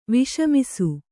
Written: ವಿಷಮಿಸು

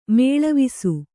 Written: ಮೇಲ್ವಾಸು

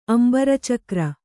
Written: ಅಂಬರಚಿಕುರ

